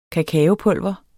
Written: kakaopulver

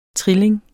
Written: trilling

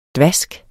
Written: dvask

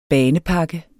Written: BANEPAKKER

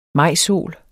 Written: majsol

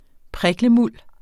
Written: priklemuld

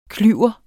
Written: klyver

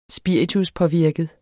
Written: spirituspåvirket

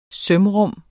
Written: sømrum

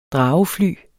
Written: dragefly

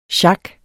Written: sjak

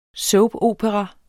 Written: soapopera

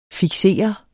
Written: fiksere

